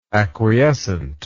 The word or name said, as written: acquiescently